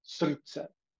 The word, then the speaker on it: srdce: The word for the day is pronounced srdce.